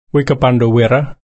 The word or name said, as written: wikaparndo-wirra